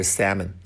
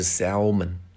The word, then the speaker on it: salmon: 啊，注意一下这个 salmon，s a l m o n，虽然它有一个 l，但是它那个 l 是不发音的啊，念出来是 salmon 啊，不是 salmon 啊，salmon。